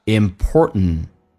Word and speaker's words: important: important 在美式发音当中，当 t 跟 and 组合， t 一般不发音，就变成一个阻塞，气流阻塞一下 important 跟 in 还有一个连音 nt 组合，不是用 t 连音，用 n 进行连音，把 t 去掉就变成 ning。